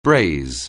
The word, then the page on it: braise: anglais